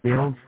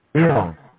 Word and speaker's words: Bill: It's Bill Steele. What's your claim number? – I'm sorry, what was your name? – Bill Steele. – Can you spell that for me, please? Your phone's really scratchy. – Bill. B-I-L-L. Steele. – Oh, S-T-E-E-L? – Mm-hmm.